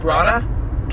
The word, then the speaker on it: Branagh: Uh, I, How do you pronounce that? Braun. Kenneth Branagh.